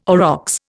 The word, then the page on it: aurochs: Indian